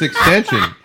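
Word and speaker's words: XXXTentacion: The number 8 song is Moonlight by somebody called XXX Tentacion. – It's extension.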